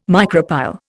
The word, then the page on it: micropyle: Indian